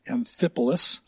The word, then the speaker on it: Amphipolis: Amphipolis and Apollonia, they came to Thessalonica where there was a synagogue of the Jews.